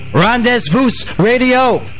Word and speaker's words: Rendezvous: Yes, welcome back, Rendezvous Radio. That's if you say it as you spell it, but it's Rendezvous Radio.